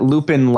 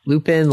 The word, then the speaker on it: Loopin: We're not looking at Lupin like a werewolf, right? – No. Lupin like L-O-O-P-I-N.